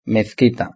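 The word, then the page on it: la mezquita: Spanish (Spain)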